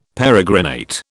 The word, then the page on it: peregrinate: British English